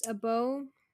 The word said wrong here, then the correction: bomoh: Uh, looks like you say it as bomoh.